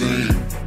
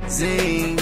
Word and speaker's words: zine: It's pronounced zine. Zine, zine, zine.